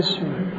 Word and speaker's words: شیر: نه، اسم جد شیره بود است، میگه شیره میکشیده، اسم جد شیره. شیر دیگه، شیر، همین شیری که شیر را اشتباه تلفظ نکنید، شیر.